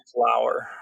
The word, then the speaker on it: Lower: I'm not claiming him to be my friend, but it is pronounced Lower. – It's Lower. It's L-O-W-E-R. Low. Correct. Okay, let's just... Take the ER off. What's the word? Take the ER away. What's the word? Low. – Mm-hmm. – And pronunciation rhymes with power. Flower.